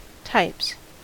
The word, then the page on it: types: American English